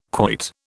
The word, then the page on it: quoit: British English